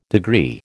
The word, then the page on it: degree: American English